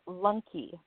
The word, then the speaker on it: Lunke: But her last name is like Lunk, L-U-N-K-E, is Rain. Rainy, is it Lunky or Lunk? How do you say your name? – It is Rainy Lunky.